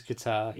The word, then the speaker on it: Qatar: It is Qatar.